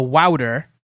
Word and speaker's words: Wouter: Say O-U in Wouter like you would say it in sound, which means ow. So Wouter.